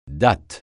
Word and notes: date: francais

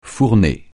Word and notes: fournée: francais

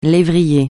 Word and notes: lévrier: francais

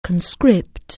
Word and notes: conscript: anglais

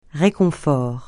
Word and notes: réconfort: francais

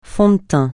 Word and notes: fond de teint: francais

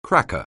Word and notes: cracker: anglais

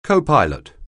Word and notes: copilot: anglais

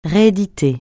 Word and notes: rééditer: francais

